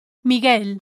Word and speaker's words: Miguel: Number one, the letter U is silent in the cluster G-U-E, ge. For example, in previous lessons we saw the words l'hamburguesa, the hamburger, l'hamburguesa, and Miguel, Michael, Miguel. Rule number two says, the letter U is silent in the cluster G-U-I, gi.